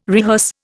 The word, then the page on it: rehearse: Indian